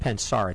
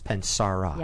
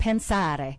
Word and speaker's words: Pensare: It's not Pensar, it's Pensara. – Yeah, you have to use the hand, Pensare. – Okay. – It's Italian. It means thinking.